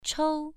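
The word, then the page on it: chou: tone 1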